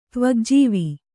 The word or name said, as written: ತ್ವಗ್ಜೀವಿ